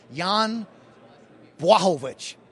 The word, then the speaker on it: Jan Błachowicz: UFC 239, and I'm going to pronounce this correctly, Jan Błachowicz.